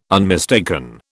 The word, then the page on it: unmistaken: British English